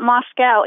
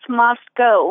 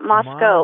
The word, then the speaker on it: Moscow: We get Moscow all the time, but it's not Moscow. It's Moscow. We pronounce it Moscow, Moscow.